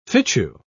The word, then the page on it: fitchew: British English